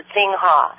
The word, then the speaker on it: Xinhua: Yes, I believe you pronounce it Xinhua.